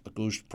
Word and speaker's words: Auguste: Yes, the Auguste is with an E and the August is without. So my name actually officially ended up as being Joseph August with an E, Auguste properly pronounced, and Bro pronounced Briault.